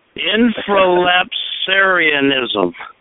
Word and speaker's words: infralapsarianism: Infralapsarianism.